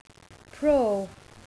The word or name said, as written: pro